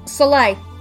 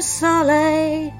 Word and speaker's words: soleil: And Gavin's saying I've always pronounced this as Soleil, but I'm guessing I'm a smidge wrong. Um, Soleil. Nous sommes du soleil.